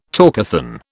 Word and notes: talkathon: British English

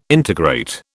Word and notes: integrate: British English

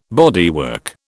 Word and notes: bodywork: British English

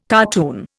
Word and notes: cartoon: Indian

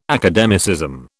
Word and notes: academicism: British English

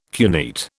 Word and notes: cuneate: British English

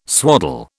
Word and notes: swaddle: British English

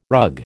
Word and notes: rug: American English

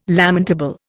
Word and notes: lamentable: Indian

